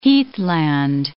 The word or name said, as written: heathland